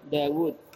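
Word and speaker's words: Dawud (داود): Dalnya panjang, dalnya wawnya juga panjang. Dawud. Nah, sebenarnya wawnya ada dua sih, dibuang dalam penulisan, dalam pengucapan tetap dibaca, dalam pengucapan tetap dibaca. Dalam penulisan wawnya tinggal satu, dalam pengucapan tetap dua. Tetap di mat Dawud.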